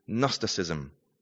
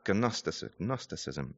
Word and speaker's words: Gnosticism: And the particular heresy that John and his contemporaries had to deal with was called Gnosticism, with a silent G at the start. Gnosticism.